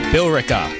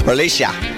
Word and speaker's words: Billerica: Bill Eric. It's Billerica? Bear and Bill? What? How do you pronounce that? Billerica. There's an E in there. – Billerica. – Billerica, maybe? – Billerica. – Oh, that makes sense. Just leave out the E.